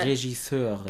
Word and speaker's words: Regisseure: Also oft ist es ja so, und also dann sagen ja auch gerne mal die Regisseure, ist das richtig ausgesprochen? – Ja, Regisseure.